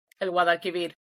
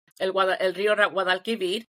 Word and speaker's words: Guadalquivir: El Guadalquivir. – Guadalquivir? – El río Guadalquivir.